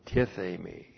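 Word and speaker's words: Tithemi (τίθημι): And then we have the verb tithemi. T-I-T-H long E-M-I. Tithemi.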